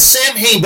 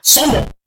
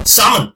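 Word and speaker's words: Samhain: It's Sam Hain, but that's not how it's pronounced in Irish. It's like, Somo. – I know. – Summon.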